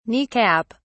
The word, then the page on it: knee cap: British English